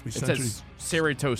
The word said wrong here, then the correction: Cerritos: Am I saying it right? C-E-R-R-I-T-O-S. Saratos Center. – C-E-what? – C-E-R-R. – Cerritos Center. – Yeah, you know, it's there's some Spanish. Cerritos.